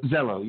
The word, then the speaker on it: Zello: Zello.